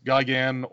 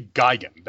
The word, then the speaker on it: Gigan: So there was for a long time, the monster, the pronunciation you got in the dubs was Gigan. Like, if you watch Godzilla versus Gigan or Godzilla versus Megalon, they say Gigan. That is how his name was pronounced.